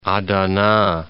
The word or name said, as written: Adana